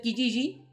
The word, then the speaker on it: Kijiji: se escribe, se escribe Kijiji con K. Kijiji con, J, dos J. Kijiji. ¿Ya? Se pronuncia Kijiji.